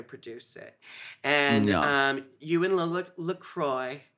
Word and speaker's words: LaCroix: You said it right. We were just talking about her last name is a litmus of how fancy someone is, because sometimes people are like, Le Quoi? Le Quoix? Or LaCroix, if you're, really not fancy.